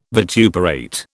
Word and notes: vituperate: British English

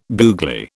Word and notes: googly: British English

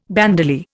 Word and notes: bandolier: Indian

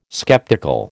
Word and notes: skeptical: American English

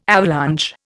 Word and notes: avalanche: Indian

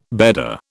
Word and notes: bedder: British English

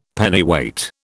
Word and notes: Pennyweight: British English

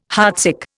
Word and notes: heartsick: Indian

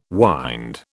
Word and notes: wynd: British English

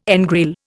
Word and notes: engrail: Indian

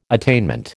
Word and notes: attainment: American English

